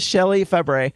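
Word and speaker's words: Shelley Fabares: It is Shelley Fabra. – Shelley Fabre. That's not how you spell it, but yes, it is Shelley Fabre.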